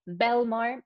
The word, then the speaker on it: Belmar: Belmar, Belmar, like the town in New Jersey.